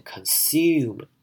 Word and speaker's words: consume: consume 我们可能听影片当中会念成 consume 会有一个 u 的声音跑出来，那是比较英式的念法。那我们在这边呢，我们就念成 oo 的声音，念成 consume 这样的声音就好了。